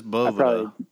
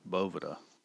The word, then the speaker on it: Boveda: It is Boveda, for anybody that's listening. It actually is Boveda.